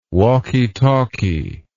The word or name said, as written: walkie talkie